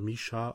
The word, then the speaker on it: MISIA: Wird geschrieben M-I-S-I-A, all caps, aber wird scheinbar Misha ausgesprochen, laut Wikipedia.